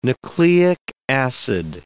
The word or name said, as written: nucleic acid